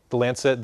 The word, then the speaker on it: Lancet: Did I say that right, Judy? The Lancet, thank you.